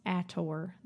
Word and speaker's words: ACOTAR: I believe it is pronounced Acotar. That's how they read Acotar. It in the book.